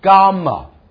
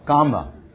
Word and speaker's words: gamma: 要第三个是子音 gamma 啊 gamma，所以你看发现它这个音名是 gam。看到吗？gr gam 啊，它是用这个来帮助你来练习那个拼音 gam 要马啊。那这个是 g 的发音，等于英文 g 的发音啊啊，像 gun 啊 go 这些都是 g 的发音。